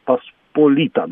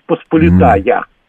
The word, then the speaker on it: Посполита: Да, и Речь Посполита. Посполита, да, на предпоследний слог ударения. Речь Посполита. А у нас... Речь Посполита. А у нас она идет практически всегда как Речь Посполитая.